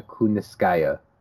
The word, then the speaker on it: Kunitskaya: They did it for Yana Kunitskaya because I think we've we've been pronouncing it Kunitskaya Kaya, and was it Anik was on the broadcast last night who was like, "It's Yana Kuniskaya."